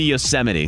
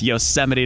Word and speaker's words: Yosemite: Because President Trump mispronounced it. – Ah, so that, I forgot like, that. – In August. – He mispronounces a lot. – It should be Yosemite. – God. – It's Yosemite National Park.